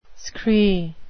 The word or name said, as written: scree